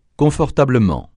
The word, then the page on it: confortablement: francais